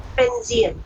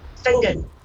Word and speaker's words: Finzean: Place spelled F-I-N-Z-E-A-N. Fenzian. Pronounced Fingen.